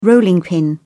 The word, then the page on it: rolling pin: British English